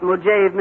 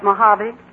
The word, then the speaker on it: Mojave: Real Death Valley prospectors don't call it Mojave, Mr. Newton, but Mojave.